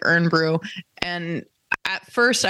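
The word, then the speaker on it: Irn-Bru: It's spelled IRNBRU, Urn Brew.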